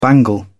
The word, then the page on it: bangle: British English